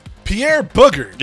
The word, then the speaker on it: Bouguer: Earlier, I was looking up the pronunciation of Pierre's last name. One of them was Booger, and I was gonna go with Booger. – Pierre Booger.